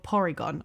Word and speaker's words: Porygon: Trainer be transported inside of a computer where they fought a Pokemon called Porygon.